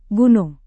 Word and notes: gunung: Indonesian

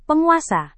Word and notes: penguasa: indonesian

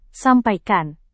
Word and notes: sampaikan: indonesian

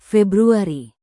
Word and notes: februari: indonesian

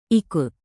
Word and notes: ikut: indonesian

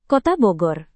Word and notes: kota bogor: indonesian

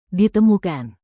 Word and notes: ditemukan: Indonesian

